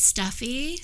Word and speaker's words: Stephie: My Instagram is Steffi, so STEPHIE.Serena.